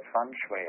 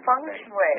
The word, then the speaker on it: feng shui: You can even pronounce that feng shui. – Oh, feng shui.